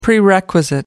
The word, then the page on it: prerequisite: American English